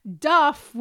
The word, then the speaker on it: duff: Duff was a northern pronunciation of dough.